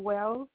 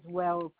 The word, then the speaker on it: Wells: Yeah, it's Kayla Wells. – Can you spell your name for me? Make sure I spell it correct. – K-A-Y-L-A, and the last name is Wells, W-E-L-L-S. – W-E-L-L-S? – Yes.